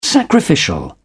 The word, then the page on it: sacrificial: British English